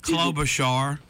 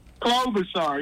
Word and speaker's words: Klobuchar: Klobuchar. – Klobuchar, yes.